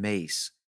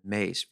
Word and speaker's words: Myss: Mace. And I believe her last name is spelled M-Y-S-S, Carolyn Mace.